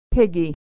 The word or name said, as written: piggy